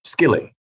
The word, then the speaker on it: Skilly: And it is also the entrance to Skilly, S K I L L Y, skilly.